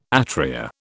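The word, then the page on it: étrier: British English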